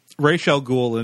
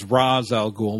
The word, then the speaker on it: Ra's al Ghul: For the listeners and viewers who aren't aware, Ra's al Ghul, and that's how you're supposed to say his name, often has his name mispronounced in various media as Ra's al Ghul.